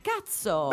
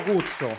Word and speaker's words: Kutso: scrive K-U-T-S-O, sì. si legge per me cazzo. – Benvenuti, benvenuti. a cazzo, benvenuti. Buonasera. – Grazie. Io dico, io dico cuzzo.